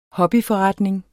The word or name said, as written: hobbyforretning